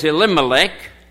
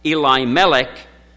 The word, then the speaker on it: Elimelech: And the name of the man was Elimelech, perhaps more correctly pronounced Eli-Melech, but since I was a kid, it's been Elimelech, so Elimelech it will be tonight.